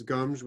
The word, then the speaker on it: Gomes: Yeah, the Portuguese are such mellow, because Gomes is a G-O-M. You know, in Spanish it's Gomez, but in Portuguese it's Gomes with an S.